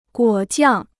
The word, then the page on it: 果酱: guǒ jiàng